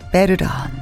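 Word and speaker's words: veteran: 베르런, 베르런들입니다. 우리식 발음으로 하면 베테랑, 바로 참전 용사들입니다.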